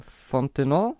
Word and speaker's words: Fontenot: Genau, der dritte neue ist Saints Director of Pro Scouting, Terry, das Französisch ausgesprochen, Fontenot.